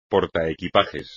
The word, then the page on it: portaequipajes: espagnol